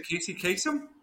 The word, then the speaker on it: Casey Kasem: Did I say it right? Is it Casey Kasem? Casey Kasem, yes.